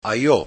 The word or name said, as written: Ajo